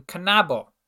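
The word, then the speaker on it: knabo: Both K and N are pronounced in kanabo.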